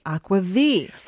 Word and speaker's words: aquavit: Aqua V. – Let's talk about Aqua. – What is Aqua V? Spelled A-Q-U-A-V-I-T.